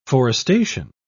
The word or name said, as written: forestation